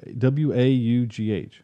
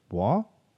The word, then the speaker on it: Waugh: So, so the director of this movie, um, the director Rick Roman Waugh, I guess Wof Woff W A U A W A U G H. How would you pronounce that? Waugh.